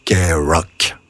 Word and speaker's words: KROQ: Son call letters, K-R-O-Q, son indicatif, si vous préférez, s'efface alors au profit de sa prononciation, qui colle si bien alors à sa couleur musicale. Elle devient K-Rock.